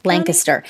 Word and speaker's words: Lancaster: Lancaster. If you don't say Lancaster, people will be very upset with you.